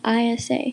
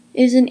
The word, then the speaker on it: Isa: Even though a lot of people get my name wrong and call me Isa, the correct way to say my name is Isa, so the I makes an E sound.